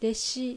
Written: Deshi

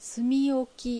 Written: Sumioki

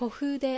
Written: Kofude

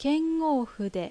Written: Kengoufu

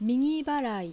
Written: Migibara

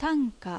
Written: Tanka

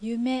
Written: Yume

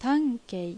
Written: Tankei

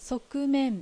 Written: Sokumen

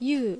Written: Yuu

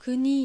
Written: Kuni

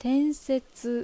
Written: Tensetsu

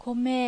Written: Kome

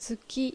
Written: Tsuki

